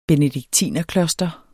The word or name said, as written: benediktinerkloster